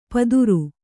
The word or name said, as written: ಪದುರು